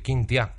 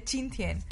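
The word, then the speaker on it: Qingtian: Eh, me dijo que casi todos los chinos que había en España venían de Quintia, que a lo mejor es la. que, no sé qué cojones es. Que seguro no se pronuncia así. bueno, se escribe pero pues, se dice Chintian.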